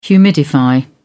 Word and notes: humidify: British English

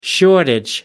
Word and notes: shortage: American English

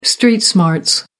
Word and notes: street smarts: American English

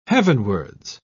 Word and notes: heavenwards: British English

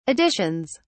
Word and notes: additions: British English

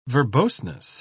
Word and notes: verboseness: British English

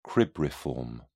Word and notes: cribriform: British English

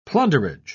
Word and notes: plunderage: British English

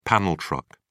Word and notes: panel truck: British English

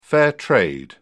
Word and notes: fair trade: British English

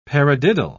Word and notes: paradiddle: British English

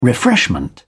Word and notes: refreshment: British English

